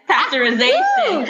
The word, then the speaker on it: pasteurization: Is it pasteurization? I may be mispronouncing it. – Yes, pasteurization. Correct. Okay, you got 2.5 points.